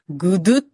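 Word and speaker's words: gudeg: で、まず一つ目がですね、ちょっとこれ日本人には発音難しいんですけれども、グドゥっていうのと、クレチェックって言います。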